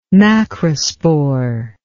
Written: macrospore

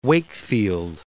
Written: Wakefield

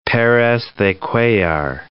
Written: Perez de Cuellar

